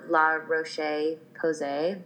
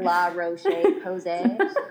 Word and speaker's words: La Roche-Posay: I'm just using the La Roche-Posay. It's a beautiful pronunciation. – It's very French sounding. – La Roche-Posay.